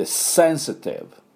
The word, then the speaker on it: sensitive: これで sensitive、 sensitive、これですね。ポイントはね、よろしいでしょうか。では私の後について発音いきましょう。 sensitive、 sensitive。最後 V の音ですね。 V、携帯バイブ。 V、 F の唇をね、前の歯に当てて、フからフフと変化するってですね。